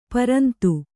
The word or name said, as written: ಪುರಂತ